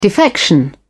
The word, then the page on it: defection: British English